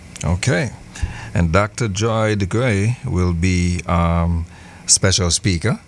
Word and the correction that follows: DeGruy: Let me give the pronunciation of that. It's Joy DeGruy. – DeGruy.